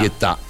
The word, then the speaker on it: Guetta: È Guetta.